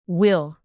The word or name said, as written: will